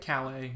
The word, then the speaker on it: Calais: and that is how you pronounce it, right? – Calais. Or, I always pronounced it Calais in my mm-hmm.